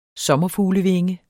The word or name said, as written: sommerfuglevinge